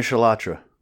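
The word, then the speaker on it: Chalotra: Anya Chedra? Anya Shalatra. – Anya Shalatra. Shalatra. – Not cilantro. No. That's a spice. That's not it.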